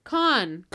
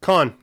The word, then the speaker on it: Caen: I have the pronunciation here. – Okay, let's listen. Con. – Con. There we go.